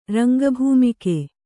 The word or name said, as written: ರಂಗಭೂಮಿಕೆ